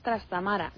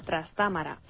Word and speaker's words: Trastámara: No es Trastámara, sino Trastámara, así como no es Brácara Augusta, sino Brácara. Por lo demás, te felicito y especialmente por el fichaje de Pepín las noches de los martes.